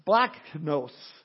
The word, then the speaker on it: splagchnos: Now, what's interesting is that this word for tender-hearted in the Greek, it's the word splachnos. S-P-L-A-G-C-H-N-O-S, for those that want to know how it's spelt.